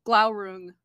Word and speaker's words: Glaurung: Glaurung? – Glaurung.